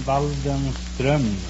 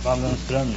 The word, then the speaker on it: Waldenström: No, it's an umlaut from the O. In Sweden, you pronounce the name Waldenström. – Waldenström? – Waldenström, yes.